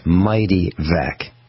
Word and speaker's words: Mityvac: The term was Mighty Vac.